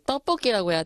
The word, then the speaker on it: 떡볶이: 아무리 급하게, 아무리 배고파도 그렇지 텍바퀴 말고 떡볶이라고 해야 되는데 But I do understand.